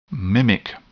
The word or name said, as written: mimic